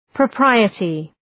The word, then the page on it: propriety: prə’praıətı